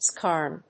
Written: skarn

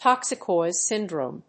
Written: toxic oil syndrome

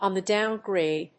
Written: on the down grade

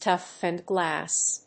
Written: toughened glass

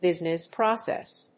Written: business process

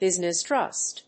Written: business trust

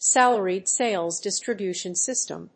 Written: salaried sales distribution system

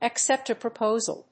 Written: accept a proposal

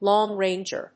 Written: long-ranger

